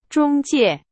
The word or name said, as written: 中介